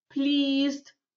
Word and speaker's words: pleased: Здесь у нас pleased со звуком «и», а здесь pleasure со звуком «э».